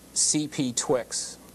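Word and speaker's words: CPTWG: And the last group there, the CPTWD, is pronounced CPTWIX. Okay, the CPTWIX has been meeting once a month in Burbank for the last 41 months.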